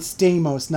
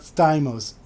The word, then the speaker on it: Stamos: Like, it's pronounced John Stamos, not Stamos.